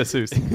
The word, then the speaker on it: Seuss: I approve of that pronunciation. – Hang on, just so we're clear, is that Dr. Seuss to the rest of us? – Dr. S-E-U-S-S. – Yeah, Dr. Seuss. But Dr. Seuss is probably the perfect pronunciation.